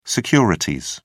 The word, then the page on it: securities: British English